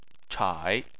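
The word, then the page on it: chai3: tone 3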